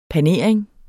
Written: panering

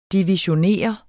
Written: divisionere

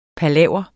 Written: palaver

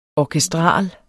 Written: orkestral

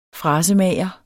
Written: frasemager

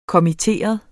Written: kommitteret

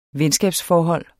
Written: venskabsforhold

